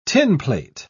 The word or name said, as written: tinplate